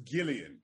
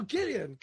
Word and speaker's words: Gillian: Because it's pronounced Gillian. – It's a hard G. – Oh, Gillian. – Yeah, oh, sorry. Because Gillian and Jillian are spelled the same. One is English, one is Irish. I'm the Irish version, the hard G.